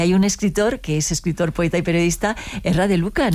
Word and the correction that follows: Erri de Luca: Erri de Luca, sí, de Luca.